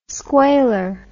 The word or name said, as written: squailer